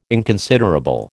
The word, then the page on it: inconsiderable: American English